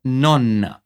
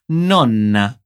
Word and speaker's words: nonna: Você percebeu que a palavra para vovó é nonna, assim mesmo, com uma doppia N. Ripeti com'è: nonna. Nonna. O cuidado com a pronúncia das duplas consoantes nessa e um bom número de palavras garante que você não diga uma palavra diferente, que é o caso de nona, que tem um N só nesse caso, que vai significar nona, isso mesmo, o número ordinal, oitava, nona, décima e assim por diante.